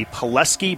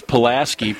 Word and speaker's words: Pulaski: 1984, the Pulaski Braves in Virginia. Had you been to any of the states in the Appalachian League, this California boy, before that? – Heck no, no. It's Pulaski pronunciation.